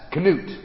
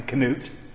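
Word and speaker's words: Knut: That's Canute, and that's how you pronounce his name, Canute, the polar bear.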